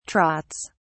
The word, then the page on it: trots: British English